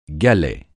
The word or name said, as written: galet